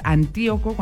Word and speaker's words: Antíoco: También está Antíoco, es Antíoco con acento en la I, Antenógenes, Elerio y Cicenando.